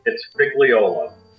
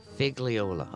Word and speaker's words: Figliuolo: Mike, how the heck do you pronounce that last name of yours? – It's Figliuolo. – Figliuolo.